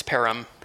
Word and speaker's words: Perham: It's Perham. – Perham, got it. – Just like Birmingham, Alabama.